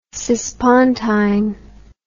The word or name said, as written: cispontine